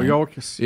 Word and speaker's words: Mayorkas: Mayorkas. – Yeah, how you say it? Mayorkas.